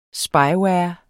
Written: Spyware

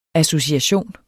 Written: association

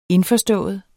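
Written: indforstået

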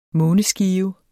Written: måneskive

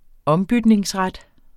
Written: OMBYTNINGEN

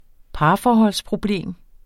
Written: parforholdsproblem

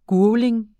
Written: gurgling

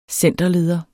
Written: centerleder

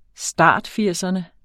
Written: startfirserne

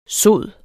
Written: sod

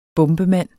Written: bombemand

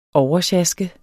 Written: oversjaske

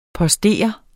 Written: postere

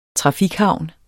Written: trafikhavn

